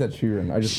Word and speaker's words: Sheeran: It is Ed Sheeran.